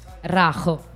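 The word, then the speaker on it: Rayo: come si legge? – Rajo. – Brava, bravissima, Vallecano, giusto? – Vallecano.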